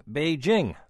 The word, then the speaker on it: Beijing: After much encouragement from our listeners, I abandoned my former pronunciation of Beijing and pronounced it saying Beijing, like jingle bells.